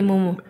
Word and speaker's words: Mumo: de, Mumo, lo dije bien, ¿verdad? – Sí, Mumo que tengo en la cabeza se llama Momo.